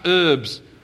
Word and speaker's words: herbs: Or if you must, herbs. But there is an H there, and in English we pronounce it herbs.